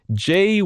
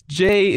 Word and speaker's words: Jaye: And that is Jay Woods. – Jay Woods. – Jay Woods. Jay is spelled J-A-Y-E.